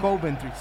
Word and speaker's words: Coventry: Coventry. Acento en la primera. – Sí. Es Coventry.